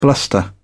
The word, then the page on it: bluster: British English